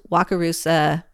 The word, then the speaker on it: Wakarusa: Why don't we at first take a look at the location, which it was Wakarusa, did I say that correctly, Indiana? – Yep.